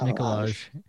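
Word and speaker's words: Nikolaj: Nicolaj. It's pronounced Nicolaj.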